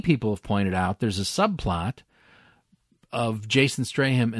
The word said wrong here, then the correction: Statham: and this little girl. Statham. – Yeah, I'm sorry. I That's can okay. never say his name right.